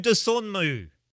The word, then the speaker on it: Dosunmu: and that's a rookie for Chicago called Ayo Dosunmu.